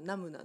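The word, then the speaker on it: 南無: ナムなんですよ南になしと書いてナム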